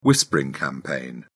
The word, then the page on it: whispering campaign: British English